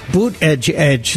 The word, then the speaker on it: Buttigieg: Buttigieg, that's how he told us to pronounce his name.